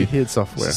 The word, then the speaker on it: ID Software: Non, mais la prononciation, je remercie Denis Talbot pour ça. – Ouais, c'est ID Software.